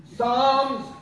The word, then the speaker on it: Psalms: Psalms is pronounced Palms.